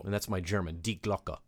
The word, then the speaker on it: Die Glocke: So it's actually two words, D-I-E, as like die, as we would pronounce it, and Glocke, not Glock like the gun, but with an E at the end of it.